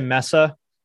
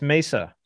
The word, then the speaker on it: Mesa: So I'm offended when I hear people say Mesa. I'm offended. – That's not how it's said. – There aren't two S's. It's Mesa.